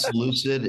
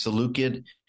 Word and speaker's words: Seleucid: when the Maccabees had to rebel against the Seleucid dynasty occupying Israel. – I didn't think we were going to get into the Seleucid dynasty. It's not Seleucid, it's Seleucid.